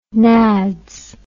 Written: nads

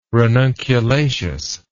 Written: ranunculaceous

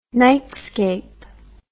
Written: nightscape

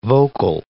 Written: vocal

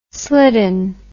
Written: slidden

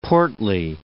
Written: portly

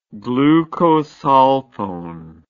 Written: glucosulfone

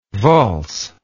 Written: waals

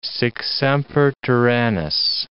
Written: sic semper tyrannis

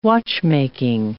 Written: watchmaking

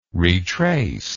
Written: retrace

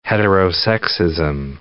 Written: heterosexism

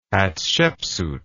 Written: Hatshepsut

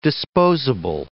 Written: disposable

